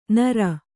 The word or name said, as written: ನಾರ್